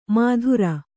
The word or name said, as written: ಮಾತುರ